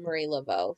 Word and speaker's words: Marie Laveau: So are you going to tell me about Marie Laveau? Am I saying that right? – Yeah, you nailed it. I am going to tell you about Marie Laveau.